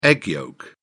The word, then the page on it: egg yolk: British English